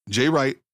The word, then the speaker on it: Jay Wright: Has it really sunk in that you, Jay Wright, are in the Basketball Hall of Fame? – Uh, no, you, you said it right.